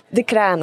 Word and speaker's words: Decraene: Say it right. – Decraene. – Decraene, that's right. I had the wrong emphasis again.